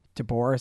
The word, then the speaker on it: DeBoer: There's a lot of buzz about DeBoer. Is that how you say his name? – Mm-hmm.